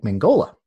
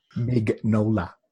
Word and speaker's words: Mignola: I'm sorry to say that again because I've mispronounced his name. This is an Elseworld story by Brian. – I believe in you. – This is an Elseworld. Together. Mignola.